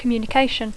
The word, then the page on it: communication: English